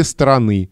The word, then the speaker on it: стороны: Диктор произнес правильно обе стороны. Ударение на окончание, так как здесь существительное в родительном падеже единственного числа, а не в именительном падеже множественного числа.